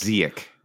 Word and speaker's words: Ziyech: I, I, think he's got the hard ch at the end. I could be wrong about this, but I think Ziyech. it's Ziyech. – Yeah. – Ziek.